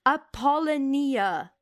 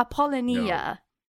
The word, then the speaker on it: Apollonia: I'm gonna pronounce this wrong. Apollonia. – No. Apollonia.